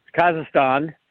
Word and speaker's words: Kazakhstan: Yeah, well, I mean, they're going to Kazakhstan. I'm not pronouncing it right. You'll correct me. – Kazakhstan? – Kazakhstan.